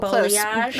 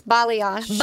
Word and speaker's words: balayage: Now, one of the things that is like a hot word for gals looking to do something different is this balayage. Am I saying it right? – Oh, balayage. Close. Yes, balayage.